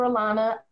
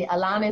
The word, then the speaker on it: Alauna: Anyway, you can find me on Facebook, Instagram, Twitter under Dr. Alana. I'll spell it for you because folks always be messing my name up. It's D-R. And Alana is spelled A-L-A-U-N-A. Don't forget the U. A-L-A-U-N-A.